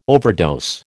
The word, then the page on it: overdose: American English